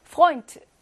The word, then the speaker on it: Freund: Freund, 에우. 이거 뭘로 발음해? 오이로 발음하죠? 오이, 오이.